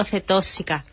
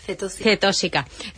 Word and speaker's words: cetósica: Hablamos de las enfermedades raras y vamos a poner en conocimiento de una en concreto, la hiperglicinemia, no sé si lo digo bien, hiperglicinemia no cetóxica. Cetósica.